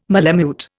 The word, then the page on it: malemute: Indian